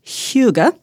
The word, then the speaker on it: hygge: As we head into the winter months, perhaps you've seen that people are talking about the Danish idea of hygge, spelled H-Y-G-G-E.